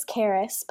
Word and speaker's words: Xaris: My name is Xaris, but it's spelled a little funky because it's a Greek word.